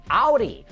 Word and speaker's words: Audi: Hey, what starts with A and rhymes with howdy? That's right, Audi.